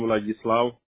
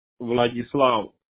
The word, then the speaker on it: Vladyslav: The nickname of Vlad, V-L-A-D. The full name, Vladislav. – How do you spell that? – Vladislav. V-L-A-D-Y-S-L-A-V.